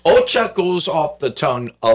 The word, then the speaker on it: OCCAA: The spelling of the, if you put the letters together, it's OCCAA. It is pronounced OCHA.